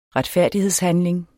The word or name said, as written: retfærdighedshandling